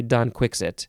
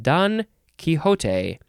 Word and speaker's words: Don Quixote: Because it is spelled D-O-N, capital D-O-N, next word, capital Q-U-I-X-O-T-E. I would never say Don Quixit, and I did not realize the British often say that. Don Quixote, I think, is the most proper way to say it.